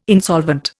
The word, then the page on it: insolvent: Indian